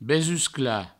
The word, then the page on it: besusclar: Provençal; besuscla